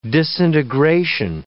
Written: disintegration

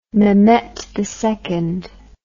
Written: mehmet ii

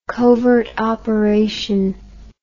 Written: covert operation

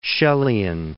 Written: Shelleyan

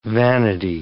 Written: vanity

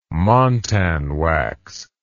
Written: montan wax

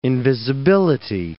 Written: invisibility

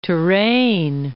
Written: Touraine

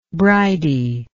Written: bridie

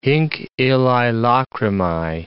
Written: hinc illae lacrimae

